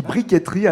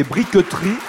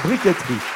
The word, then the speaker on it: briqueterie: On dit briqueterie alors qu'il n'y a pas d'accent sur le E, ni de T, d'ailleurs. On pourrait prononcer briqueterie, mais on dit communément briqueterie. Donc ça s'écrit B-R-I-Q-U-E-T-E-R-I-E, la briqueterie.